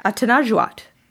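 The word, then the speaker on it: Atanarjuat: I've had separate exchanges with both Emma and Martin about this issue, and both of them responded with, how about we talk about Atanajuat? Did I say that right? – Sounds right.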